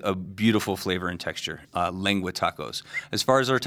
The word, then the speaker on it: lengua: But then you also have, is it called lengua or lengua? – It's pronounced lengua.